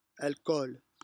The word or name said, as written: alchool